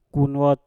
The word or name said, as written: kunwot